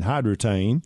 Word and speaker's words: Hydretain: And it's spelled H-Y-D-R-E-T-A-I-N, Hyde Water Retain, Hydratain.